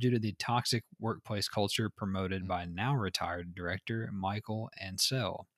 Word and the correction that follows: Michel Ancel: Apparently you say his name Michel Ancel, whatever.